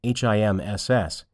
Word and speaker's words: HIMSS: Two possible sources of EHR information are the Online Buyer's Guide, published by the Health Information Management System Society, HIMSS, pronounced HIMS, and the resources available from KLAS, pronounced Class Research, LLC.